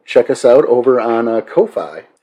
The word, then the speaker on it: Ko-fi: That's and then you put the dash in there, so you have to emphasize the syllables. – Right. – Ko-Fi.